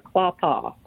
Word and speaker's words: Quapaw: Quapaw. Did I pronounce that right? – Quapaw.